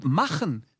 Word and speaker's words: machen: Yeah, but this guttural thing, machen, that's really machen. Good because normally, Americans, they tend to do machen instead of machen.